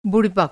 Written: बुड़िबक

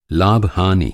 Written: लाभ

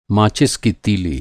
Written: माचिस

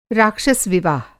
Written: राक्षस विवाह

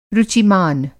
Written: रुचिमान

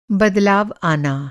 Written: बदलाव

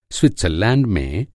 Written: स्विट्ज़रलैंड

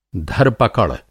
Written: धर-पकड़

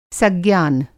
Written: सज्ञान

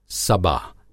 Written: सबा

